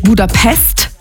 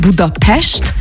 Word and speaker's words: Budapest: Also erstens appreciaten wir das schon mal, wenn man nicht Budapest sagt, sondern Budapest, weil das S immer ausgesprochen wird wie ein Sch.